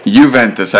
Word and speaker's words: Juventus: Were you impressed with Ronaldo's regular season debut with Juventus? Have I pronounced it correctly? – Juventus.